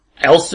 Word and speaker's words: Elst: Elst is the way to pronounce it, but it seems like it's, cause it's okay.